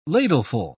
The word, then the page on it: ladleful: British English